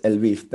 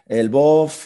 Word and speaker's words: Lviv: Por ahora aquí más o menos la gente local, aunque los que no somos naturales de aquí, la pronunciación más habitual es Lviv. Pero como has dicho, en Polonia Lvov, para Alemania Lemberg, españoles solemos llamarlo, buscarlo en Google por Leópolis.